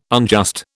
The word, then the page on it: unjust: British English